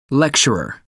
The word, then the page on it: lecturer: American English